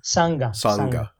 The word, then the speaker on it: Sangha: All I know right now is it's being hosted by the United Sangha, I believe. Yeah. Is that the correct pronunciation? – Yeah, Sangha.